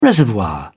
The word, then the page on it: reservoir: British English